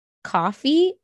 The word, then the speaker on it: Ko-fi: Someone just told me about something called, apparently it's pronounced coffee, but it's K-O-F-I. – Okay. – And I was like, that's really cute and kind of confusing because I would have said Ko-Fi.